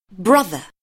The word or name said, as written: brother